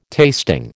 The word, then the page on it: tasting: American English